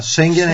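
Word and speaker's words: Schengen: Последната тастричка слагаме ударение. – Улаксаме. Наше въпа Шенген. – Шенген. Шенген. – Да, Шенген е верното.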